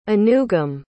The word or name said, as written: anugum